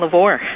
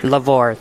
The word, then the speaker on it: LeVoir: Yes, uh, Bishop John LeVoir. – LeVoir, thank you.